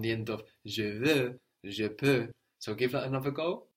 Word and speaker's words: peux: And we said that both of these are spelled EUX, no? VEUX or PEUX? And that X is silent, no? Je veux. – Je peux.